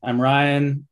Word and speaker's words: Ryan: I'm Ryan. It's just like R-Y-A-N, spelled like lion.